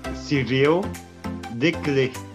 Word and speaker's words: Cyril Desclés: Cyril, me ajuda aí na pronúncia do seu nome bonitinho para todo mundo saber, porque o meu francês não vale. – O meu nome é Cyril Decré.